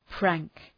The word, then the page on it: prank: præŋk